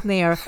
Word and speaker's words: Nair: Nair.